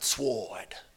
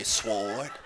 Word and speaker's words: sword: The word is sword, not sword. The W is silent. Don't ever say sword.